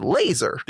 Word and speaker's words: Lazar: Now, it's spelled Lazar, right? Yeah. But they pronounce it Laser.